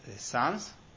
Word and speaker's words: Sens: And the captives gave the majority, and they levied the rest upon Troyes and their neighboring brethren in Sens? Is that how it's pronounced? – Yeah, sounds good.